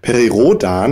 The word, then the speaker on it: Perry Rodan: dass dieses Perry Roden oder Perry Rodan, muss man es ja, glaube ich, aussprechen. – Korrekt Perry ausgesprochen, Perry Rodan.